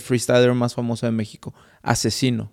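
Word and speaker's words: Aczino: Pero lo, se escribe A, e, C, Z. Entonces hay gente que no sabe cómo se pronuncia y le dicen axino. – Pero es asesino.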